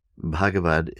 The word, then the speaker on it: Bhagavad: Bhagavad is spelled B-H-A-G-A-V-A-D. Bhagavad. B-H-A-G-A-V-A-D. Bhagavad. Second word, Gita. G-I-T-A.